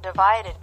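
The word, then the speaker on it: ÷: entitled Divided by, pronounced Divide, which was released on the 3rd of March 2017.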